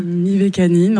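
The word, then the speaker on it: Mivekannin: Mivacanin ou Mivacanim? – Mivacanin, en tout cas c'est comme ça Mivacanin. Que moi je le prononce.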